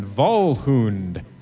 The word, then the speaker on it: Volhund: That's Floral Spectre, Jackalware, and Volhund. That's a W pronounced as a V, folks.